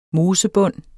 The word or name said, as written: mosebund